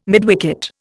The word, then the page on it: midwicket: Indian